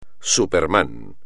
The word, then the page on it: superman: espagnol